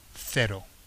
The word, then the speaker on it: cero: So, that was, if you notice, the zero was pronounced cero, okay? And ten was diez, okay? Because that's typically the peninsular pronunciation in Spain.